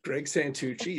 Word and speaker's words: Greg Santucci: Um, so, Greg, tell folks who you are and um, what you do, and then we'll jump into our conversation well, Greg Santucci, you said it right.